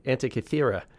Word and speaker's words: Antikythera: There's a number of images, um, including the, and I'm, I'm going to try to say it right, the Antikythera uh, mechanism. Is that right? The Antikythera? – The Antikythera uh,